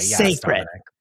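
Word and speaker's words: Cécred: Before we get into the fix, I want everyone to know that Beyonce's hairline, I have been mispronouncing it the entire time. I thought that this hair care line she came out with, I don't even want to say what I was calling it before, but it's spelled C-E-C-R-E-D. I didn't know that the C-E was Beyonce. – Sacred. Dear God.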